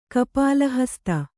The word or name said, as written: ಕಪಾಲಹಸ್ತ